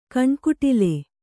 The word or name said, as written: ಕಣ್ಕುಟಿಲ